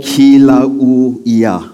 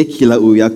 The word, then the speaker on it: Kilauea: But we went to this place, I didn't pronounce it correctly, called Kilauea. Okay, Kilauea Crater.